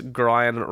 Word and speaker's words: Grian: And his reason was Grian rhymes with lion.